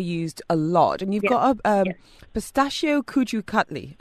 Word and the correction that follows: kaju katli: Have I said that right? Please do not be offended. – No. No, you haven't, but it's kaju cutli.